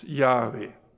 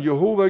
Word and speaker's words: Jahwe: Und bis heute hat man keine bessere Aussprache gefunden als Jahwe. Wer ist Jahwe? Früher hat man Jehova gesagt. Das war eine Wortbildung, die auf ein Missverständnis beruhte.